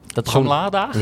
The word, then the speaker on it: Ladage: Bramladage? – Ja, Ladage. Is het hip? Moet je het anders uitspreken? – Ik zeg altijd Bramladage.